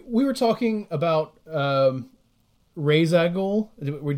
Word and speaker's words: Ra's al Ghul: I think I heard him on a podcast say it's pronounced Ra's al Ghul, but they've never done it that way in the movies.